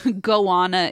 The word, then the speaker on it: goanna: I thought a Gianna, and I thought, oh, they meant to say iguana, but I guess a goanna is an animal in Australia. – Oh, my God. How do you spell that? – G-O-A-N-N-A.